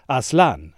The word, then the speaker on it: Aslan: In a letter written in the 1950s, Lewis confirms that he has used the Turkish word for lion, and that he intends it to be pronounced Aslan, as it is in Turkish.